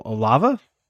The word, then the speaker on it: Olave: Olave.